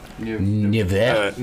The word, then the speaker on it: Nevers: And how do you say it? – Nevers. – Nevers. – Nevers. – Yeah. Get close.